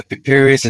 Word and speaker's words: Piri Reis: Is it pronounced Piri Reis? Piri Reis? – Yeah, Piri Reis. It's P-I-R-I-R-E-I-S.